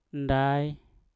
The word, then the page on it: ndai: Mpur